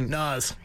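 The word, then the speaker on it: Nas: Naz. Yep. – Naz is his name? – Yeah, that's how you pronounce it. – So not like, so like Nazem Kadri.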